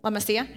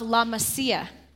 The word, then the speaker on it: La Masia: La Messia. Am I saying that right? La Messia. Table. La Masia? – Yeah. – The foundation of La Masia.